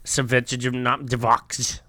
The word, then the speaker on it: Svechnikov: By the way, his name is Svechnikov. – That's a good way to pronounce it. – Yes. – Svechnikov. – Yeah, that sounded right.